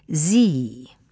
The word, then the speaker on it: Sie: The pronunciation is sie. Sprechen Sie Deutsch?